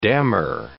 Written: dammar

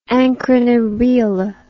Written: Ancrene Riwle

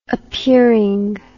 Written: appearing

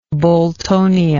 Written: boltonia

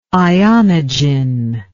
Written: ionogen

